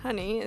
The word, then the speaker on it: HNNY: His name is spelled HNNY, but it's pronounced Honey, as you said.